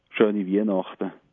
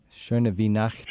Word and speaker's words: Schöne Weihnachten: Schöne Weihnachten. – Schöne Weihnachten. – Schöne Weihnachten.